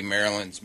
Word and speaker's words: Maryland: It is said as Maryland. Maryland. Stress on the first syllable.